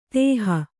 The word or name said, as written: ದೋಹಿ